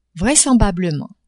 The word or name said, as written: vraisemblablement